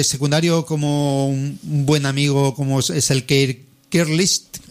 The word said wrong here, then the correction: Gilchrist: Es que esto, ¿cómo se pronuncia? Gilchrist.